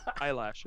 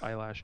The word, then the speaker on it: Eilish: Eilish. It's pronounced eyelash.